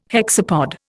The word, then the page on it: hexapod: Indian